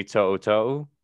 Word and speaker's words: To'oto'o: So Sam and I's wish list at the top of that was Henry To'oto'o.